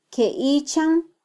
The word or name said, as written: kyeichan